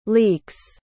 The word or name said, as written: leeks